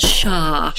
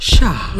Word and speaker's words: SHA: One of the most popular ones is called SHA. – SHA. – Which is spelled S-H-A.